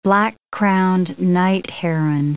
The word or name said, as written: black-crowned night heron